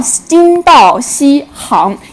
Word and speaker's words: 金道锡行: 那老师呢，给大家统一介绍一下，这个叫金道锡行，因为行是我们走路的一个动作，对吧？是一个动词，而行呢，它是指这个通道的一个含义。